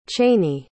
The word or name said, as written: chainey